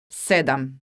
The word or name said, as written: sedam